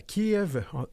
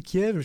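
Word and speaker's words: Kyiv: Pour marquer ce jalon, la présidente de la Commission européenne, Ursula von der Leyen, est à Kiev, on dit Kiev, je pense qu'on faudrait dire Kiev, on le prononce comme il faut en ukrainien, pour réitérer le soutien indéfectible de l'Union.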